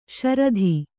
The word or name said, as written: ಶ್ರೋತ